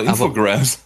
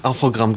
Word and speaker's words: Infogrames: Ja, ich wollte sagen, ich kenne die nämlich unter Infogrames. Aber, ja, die werden Infogramm gesprochen. Infogramm.